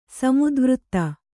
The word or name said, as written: ಸಮತಾವಾದ